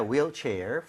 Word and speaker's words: wheelchair: 아, 발음할 때 휠이 아니라 뭐라 그랬죠? – Wheel. – Wheel, right? 우리가 흰색도 화이트라고 안 하죠? – White. – White이라고 하죠. 그래서 네. – H가 없는 느낌으로 발음할 때 wheel chair. 이라고 하는 게 맞습니다.